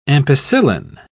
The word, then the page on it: ampicillin: British English